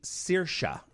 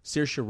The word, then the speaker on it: Saoirse: It's actually Saoirse. So just think like Seer and then Shah. Saoirse Ronan. That's an Irish name. And actually Saoirse means freedom.